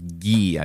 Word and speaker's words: Gee: This is June Medical Services versus Gee, I believe it's with a hard G.